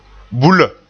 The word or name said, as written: bulla